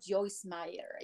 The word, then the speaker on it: Joyce Meyer: And I'm also a fan of Joyce Meyer. You know, oh, yeah. – I don't know if uh-huh. I'm pronouncing her right. – Yes, that's right.